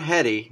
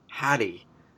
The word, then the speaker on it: Headey: And, it's not Hetty. It's not Lena Hetty. It's Lena Hattie. I found out the pronunciation of her name.